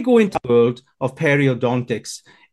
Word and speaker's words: periodontics: Effectively, when someone goes into the industry of, I'm going to say it, okay, periodontics.